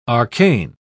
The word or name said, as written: arcane